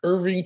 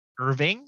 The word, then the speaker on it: Irving: Closing out my wide receiver core with uh, Miami Dolphins wide receiver Irving Fryar, who had 1,010 yards on the season and five touchdowns. – Irving or Irvin? Irving. Ving.